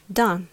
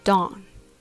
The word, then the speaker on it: done: Just be careful you pronounce it done and not dawn.